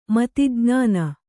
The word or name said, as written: ಮತಿಜ್ಞಾನ